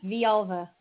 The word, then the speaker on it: Villalva: Yes, Villalva. Yes, it's, it's a Spanish last name, so the two L's make a Y sound.